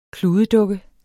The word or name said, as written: kludedukke